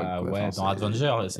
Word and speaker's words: Avengers: Bah, mais on prononce comme ça en anglais. Avengers. – Avengers. – Avengers. Les Vengeurs. – Bah, t'enlèves le D, on est bon.